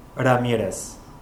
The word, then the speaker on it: Ramirez: Ramirez. – Can you please spell it? – R-A-M-I-R-E-Z.